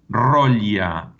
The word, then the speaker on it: Rolha: uno de los apodos que aparece más vinculados a la Argentina es uno que es Rolla, que se escribe del siguiente modo para los oyentes. Rolha.